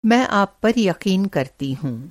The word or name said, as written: यक़ीन